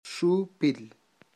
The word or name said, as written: xupil